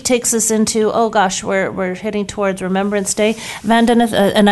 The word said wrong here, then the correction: Vandana: I said, I put the stressor on the wrong syllable, so I apologize. I said Vandana, so Vandana, thank you so much for joining us.